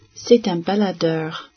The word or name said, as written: baladeur